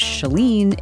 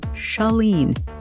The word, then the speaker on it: Chalene: It's Shalene. It's not Shalene. It's not Charlene. It's Shalene.